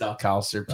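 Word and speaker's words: Alcácer: I think his name is Alcacer. – Alcacer. It's Alcacer.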